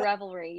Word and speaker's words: Revelry: And the last one we're going to talk about is, I hope I say this right. I have a Southern accent and everything comes off like I say it wrong. Revelry? Is that how you say it? – Yeah, okay.